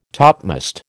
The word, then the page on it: topmast: American English